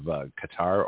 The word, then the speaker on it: Qatar: They're more sort of Al Jazeera, which comes out of Qatar or gutter, you know, depending on your pronunciation.